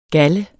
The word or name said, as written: galler